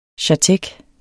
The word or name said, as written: chartek